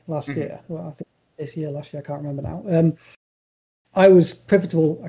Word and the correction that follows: pivotal: I can't say this word. Pivotal. – You're adding an extra syllable in there.